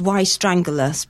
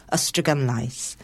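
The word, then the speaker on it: Ystradgynlais: The way that English people pronounce this was Y strangulus, but the Welsh proper way of pronouncing it was Ystrygymlais.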